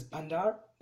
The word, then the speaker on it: Bundal: This island goes by the name of Bundal. It is pronounced as Bandar by the local fishermen.